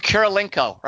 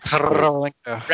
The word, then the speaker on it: Kurilenko: Olga Kurilenko. Right. It took me a moment. – Kurilenko.